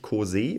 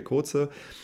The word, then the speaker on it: DJ Koze: Und zwar handelt es sich dabei um DJ Koze. Ähm, der heißt nicht Kose oder so, wie man ihn aussprechen will. Und er heißt auch nicht ganz so wie das, was ich jetzt nicht sage, sondern DJ Koze.